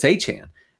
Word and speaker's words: Achane: And it's Achane. – I'm gonna invent a silent E.